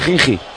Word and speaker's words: Khimki: Khimki.